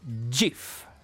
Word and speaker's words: GIF: C'è chi dice GIF, c'è chi dice GIF, e allora Steve Wilhite, che è il creatore di questo formato, dice che si pronuncia GIF. – GIF. Oh. – Però perché sta per Graphic Interchange Format, quindi dovrebbe essere graphic.